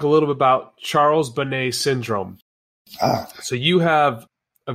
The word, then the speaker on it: Bonnet: Charles Bonnet syndrome, that's French. It was first documented in 1760 by Charles Bonnet. And the English pronunciation is Charles Bonnet. So if you want to Google this, Charles Bonnet syndrome is B-O-N-N-E-T.